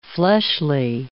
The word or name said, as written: fleshly